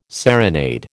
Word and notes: serenade: American English

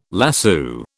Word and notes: lasso: British English

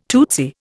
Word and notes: tootsy: Indian

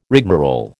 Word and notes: rigmarole: American English

